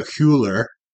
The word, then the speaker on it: Hüller: Sandra Hüller, yes, in Anatomy of a Fall. – Proper pronunciation.